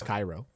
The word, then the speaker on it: Cairo: And you may say six. That's no, that's how it's pronounced. It's Cairo. – Spelled C A I R O.